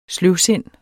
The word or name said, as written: sløvsindet